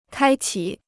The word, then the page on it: 开启: kāi qǐ